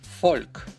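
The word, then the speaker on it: Volk: Однако в немецком она почти всегда читается как «ф». Буква «фау». Фольк, фата.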